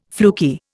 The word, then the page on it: fluky: Indian